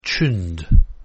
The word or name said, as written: tuned